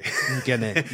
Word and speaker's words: Ngene: Ngene, yes. – So it's Guinea, correct? – Ngene. – Ngene. Okay, there's a silent N there.